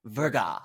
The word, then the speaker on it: Verga: Am I saying that right? Verga, hard G.